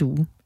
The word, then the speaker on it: Due: Nu fik jeg jo ikke udtalt det rigtigt. Det er DUE, altså ja. D-U-E.